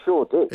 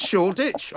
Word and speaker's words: Shoreditch: It's not Shoreditch.